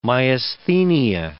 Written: myasthenia

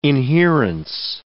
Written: inherence

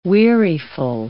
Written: weariful

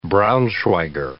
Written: braunschweiger

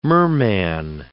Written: merman

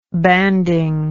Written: banding

